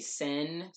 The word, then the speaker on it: cin: Yeah, because it says jinn, but the way it's pronounced in Turkish is actually sin, spelled C-I-N, so yeah, it's Turkish lore.